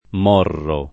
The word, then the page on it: Morro: m 0 rro; con -o- chiuso